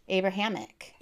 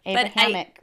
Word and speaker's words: Abrahamic: Abrahamic. – Yeah, I think. – Why does it sound like hammock? – Because it does have the word hammock in it, or the sound hammock in it. – Abrahamic religions.